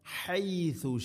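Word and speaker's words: ഹൈസു (حيث): ഫകുലു മിൻഹ ഹൈസു ഷിഅ്തും ഹൈസു ഹൈസു അല്ല ഹ ഥ ഹൈസു ഹ തൊണ്ടയുടെ മധ്യഭാഗം അതുപോലെ ഥൻ്റെ ഉച്ചാരണം പ്രത്യേകം ശ്രദ്ധിക്കണം, സീന് ആവരുത്.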